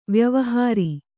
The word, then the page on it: ವಾಯುವಿಹಾರ: vāyu vihāra